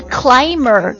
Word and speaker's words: climb: climber 这个 climb 他的 B 是不发音的